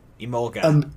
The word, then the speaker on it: Emolga: It's like Emolga.